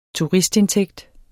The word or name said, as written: turistindtægt